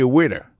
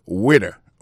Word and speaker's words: waiter: I am a waiter. Ça veut dire, moi, son garçon m'a servi dans le restaurant. Alors, moi, je prononce le waiter. Waiter, ça veut dire accent tombé sous premier syllabe là. Waiter. W-A-I-T-E-R.